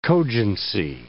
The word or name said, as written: cogency